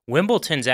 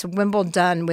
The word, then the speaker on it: Wimbledon: It's actually not with a T. It's Wimbledon with a D. – Wimbledon. I can't even say it right. – No, that's the right way.